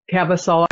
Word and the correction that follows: Caviezel: I can't pronounce his name correctly. Anyway, another Caviezel. Rabbit. Caviezel. Thank you.